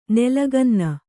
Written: ನಲ್ಲಗನ್ನೆ

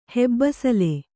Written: ಹೆಬ್ಬಿಸಿಲು

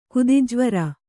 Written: ಕುದಿಜ್ವರ

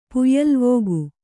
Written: ಪುಯ್ಯಲ್ವೋಗು

